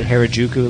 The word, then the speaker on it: Harajuku: She has modeled her life after that Harajuku life.